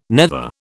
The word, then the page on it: nether: British English